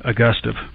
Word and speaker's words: Augustov: Augustov. Like Augusta National. Huh. So anytime you think about saying it, just think of Augusta National and add a V.